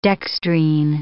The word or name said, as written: dextrine